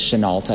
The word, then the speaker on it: Shenault: So, as we all know, Laviska Shenault, Chenault, as people were saying during the press conference today. It is Shenault.